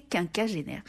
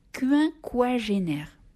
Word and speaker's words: quinquagénaire: Eh bien oui, merci à Sébastien, qui sur la même chronique de la semaine passée, remarquant que j'ai prononcé quinquagénaire, me demande ce que je pense de la prononciation quinquagénaire. Moi, je m'apprêtais à lui répondre fort poliment que cette deuxième prononciation n'existait pas. Et bien, figurez-vous qu'en fait, elle est répertoriée par tous les dictionnaires.